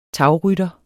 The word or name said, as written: tagrytter